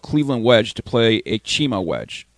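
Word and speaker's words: Txema: Pronunciation is Chima.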